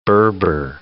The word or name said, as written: Berber